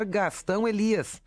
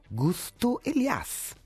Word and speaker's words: Gastão Elias: O locutor da TV australiana não soube pronunciar Gastão Elias. Saiu Gusto Elias.